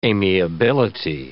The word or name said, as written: amiability